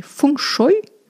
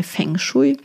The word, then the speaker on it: Feng Shui: Also Feng Shui, das kommt aus dem Chinesischen, das ist Chinesisch und richtig ausgesprochen würde es etwa so in die Richtung klingen wie Feng Shui.